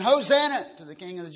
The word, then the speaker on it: Hosanna: We pronounce it Hosanna.